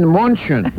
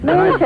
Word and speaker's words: München: They got around and they said, you mean München. – München.